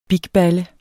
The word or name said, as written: bigballe